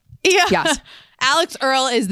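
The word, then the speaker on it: Alix Earle: Alex Earl is the Gen Z Alex Cooper. I think you said that. That's how you say it.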